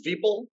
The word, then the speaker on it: Veeple: It's uh, my name is Veeple, rhymes with people. It's people with the V.